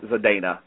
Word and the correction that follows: Saldana: Ooh, I can't say her name. Okay. – Saldana.